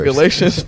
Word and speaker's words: Galatians: It's Galatians. – Galatians, right? I got it right. that was crazy. – I'm surprised we ain't canceled. – All right, bad. But it's in Galatians 5:1, and it says, "This is for freedom that Christ has set us free.